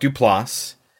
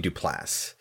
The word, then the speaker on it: Duplass: It might be Duplass, but it's not Dupla. Because there are two S's. – Dupless.